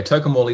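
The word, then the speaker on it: Tocumwal: Tuckumwal is on the Murray on the north side, and then just on the south side is Cobram.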